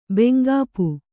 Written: ಬೆಂಗಾಪು

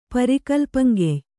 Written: ಪರಿಕಲ್ಪಂಗೆಯ್

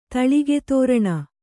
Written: ತಳಿಗೆತೋರಣ

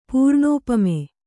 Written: ಪೂರ್ಣೋಪಮೆ

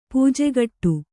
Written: ಪೂಜೆಗಟ್ಟು

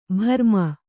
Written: ಭ್ರಮಿ

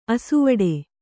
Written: ಆಸೆವಡು

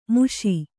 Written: ಮಿಷ

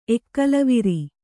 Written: ಎಕ್ಕಲವಿರಿ

